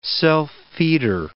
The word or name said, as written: self-feeder